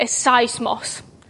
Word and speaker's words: seismos: Matthew calls it a seismos. That is the correct pronunciation. I googled it this morning. It's the Greek word for earthquake.